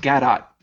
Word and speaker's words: Gadot: I think it's pronounced Gadot. – Oh yeah, it's Gal Gadot.